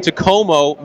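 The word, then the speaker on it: Takomo: So when I tell people it's Tacoma, I always have to spell it, because it's T-A-K-O-M-O. It's a weird word because it's a Finnish word. and the company's based in Finland. And Tacomo means like forged in Finnish.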